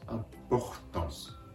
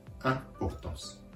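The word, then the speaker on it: importance: ここで言えば、フをような形で、アンポホ、ポホタンス、アンポホタンスと発音するところがポイントです。そして次に、五島と五松の B ボイ、ン、アンポホタンス、アンポホタンスの 2 回出てくる B ボインです。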